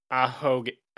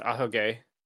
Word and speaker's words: ahoge: Yeah, it's ahoge. Yeah, it's ahoge. Or wait. – Yeah, oh, emotive yeah, ahoge. Okay.